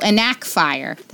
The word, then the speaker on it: Enacfire: The brand is Enkifier. I got them off of Amazon. And I have to tell you, Carrie has actual AirPods and I have these Enkifier. Oh, Enakfier. That's how you say it.